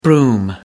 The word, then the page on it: broom: American English